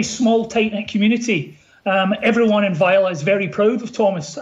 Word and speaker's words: Vejle: But obviously he comes, he's born in Vejle, or hopefully I pronounced that even almost. – Yeah, Vejle.